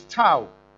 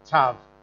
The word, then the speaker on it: tav: But if you look at the word oath in the Hebrew, the first letter is tau, which is what we're looking at there, or tav. There's two different ways of pronouncing it, but which means, if you look at it, it means mark, sign, signal, or monument.